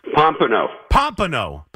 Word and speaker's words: Pompano: I hope I'm saying that right. Pompona? – Pompano. – Pompano Beach. – Yeah, why do people have trouble pronouncing it? – Pompano, I don't know. It's Pompono. It's Pompano. That's okay.